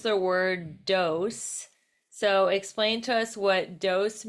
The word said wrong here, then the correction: dowsing: we pronounce it dowsing. – Oh, Yeah. – douse. That's why I'm like, douse, douse. Okay, I understand the word douse, but what does it mean in terms of feng shui and manifesting?